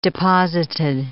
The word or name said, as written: deposited